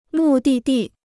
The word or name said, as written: 目的地